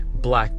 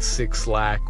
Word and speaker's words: 6LACK: His name is Black, and the way that it's spelled is 6LACK, so it's Black, but some people, they read Six Lack or Six Black, but it's actually Black.